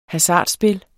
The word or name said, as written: hasardspil